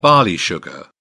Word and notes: barley sugar: British English

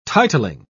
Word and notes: titling: British English

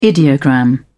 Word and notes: ideogram: British English

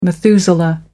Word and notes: methuselah: American English

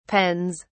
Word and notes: pens: British English

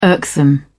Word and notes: irksome: British English

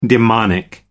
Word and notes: demonic: American English

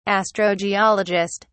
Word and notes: astrogeologist: British English

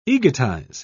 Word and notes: egotize: British English

